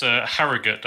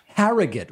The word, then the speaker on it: Harrogate: Why don't we go instead from, is it pronounced Harrogate? – It's Harrogate. – Harrogate.